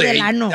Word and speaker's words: Delano: Pero se dice Delano, ¿no? – Ah, yo no sé. – No, Delano.